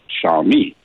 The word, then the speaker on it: Xiaomi: and the way to pronounce it is Xiao as in shower, Xiaomi.